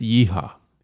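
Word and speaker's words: Wiha: I do have a small set that I built piece by piece of Weehaw, I think is how you say the German word. – It's Yeehaw.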